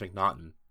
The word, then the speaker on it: McNaughton: Jon McNaughton, I guess, if you pronounce it like laughter. – No, I don't. I think it's McNaughton. – No, I think it's McNaughton too.